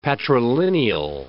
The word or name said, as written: patrilineal